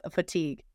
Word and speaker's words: fatigue: And I was like, fagitude. Like, I'm like, why is this such a thing? And she goes, just so you know, it's actually like fatigue.